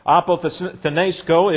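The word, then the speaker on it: apothenesco (ἀποθνήσκω): Apothenesco is the term, which is A-P-O, and then we have T-H-N. And then we have E-S-K-O, apothenesco, A-P-O-T-H-N-E-S-K, long O on the end.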